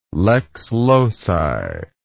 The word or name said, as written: lex loci